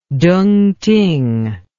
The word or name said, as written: tungting